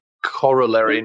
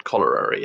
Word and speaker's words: corollary: It would be corollary, not cholerary, yes. – Corollary. – Yes. – I'm going to throw it to future Simon. Please pronounce this correctly. Thanks, past Simon. It is in fact pronounced corollary discharge.